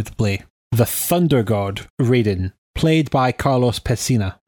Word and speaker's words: Raiden: In Japanese, as far as I know, we've watched a lot of anime, so it should be pronounced Raiden. – It should be pronounced Raiden.